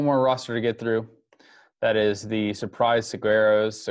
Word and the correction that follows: Saguaros: Saguaros. – I'm mispronouncing that.